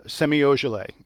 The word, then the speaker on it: Semi Ojeleye: Semi Ojeleye, whose name I probably just mispronounced terribly. – Nope. Performed that pretty well.